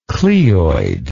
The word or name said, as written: cleoid